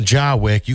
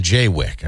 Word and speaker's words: Jaywick: We call him Jawick. You call them Jaywick. Are we saying Jawick? No, you're right. Jawick.